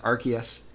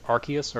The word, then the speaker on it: Arceus: There was a poll on the official Pokemon subreddit as to whether or not it is pronounced Arceus or Arceus. And Arceus won with about 70% of the vote. So just to break custom, I'm gonna go with Arceus.